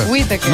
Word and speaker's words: Whitaker: Así se pronuncia. – Whitaker. – No, que como decía el otro, Whitaker.